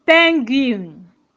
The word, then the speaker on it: penguin: You find out that while the spelling, there's no wo between after G. But why pronouncing it? You say penguin. Penguin. You hear the win at the end.